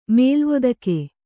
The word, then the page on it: ಮೇಲುವತ್ತಿಗೆ: mēluvattige